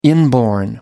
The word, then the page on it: inborn: American English